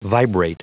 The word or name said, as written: vibrate